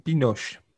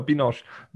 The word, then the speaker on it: Binoche: Juliette Binoche. – No, Binoche. Binoche.